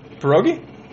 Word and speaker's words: pierogi: Ever eat a pierogi? How do you say this? – Pierogi? – It's, yeah, pie and then rogi.